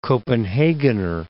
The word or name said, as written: Copenhagener